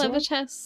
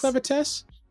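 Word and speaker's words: Clevatess: Would you say Clevertess? Is that how you pronounce Clevertess. – It? Clevertess. My Dress Up Darling Season 2,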